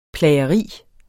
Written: PLAGERI

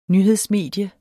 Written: nyhedsmedie

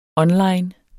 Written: online

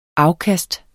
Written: afkast

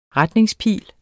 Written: retningspil

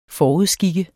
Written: FORUDSKIK

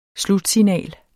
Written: slutsignal

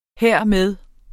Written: hermed

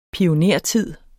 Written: pionertid

